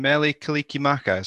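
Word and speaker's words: Mele Kalikimaka: I was the song gets played here on the radio for, is the pronunciation Melikalikimaka? Is that how you say it? – That's really good, man. That's good.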